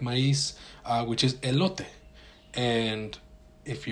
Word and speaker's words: maíz: And then I realize, oh, they're talking about maíz, which is how we say it in Spanish.